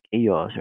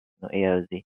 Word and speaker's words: AIOZ: However, to pronounce it is like AIOZ, right? AIOZ.